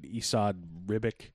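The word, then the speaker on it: Esad Ribić: Drawn by, I'll never pronounce it right, Esad Ribic. – Sounds right.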